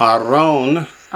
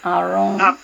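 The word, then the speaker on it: Aron (ארון): Aron. – Aron. – Is not Aaron, it means ark. Aaron is Aharon. It's got another syllable in it.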